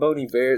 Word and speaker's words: Bon Iver: Yeah, you say it Bon Iver, it's like a French thing.